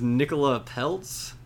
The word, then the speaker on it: Nicola Peltz: So, next is Nicola Peltz. I believe is how you say her name.